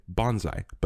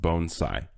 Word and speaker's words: bonsai: Usually in our English language, we say bonsai, but it's apparently pronounced bonsai.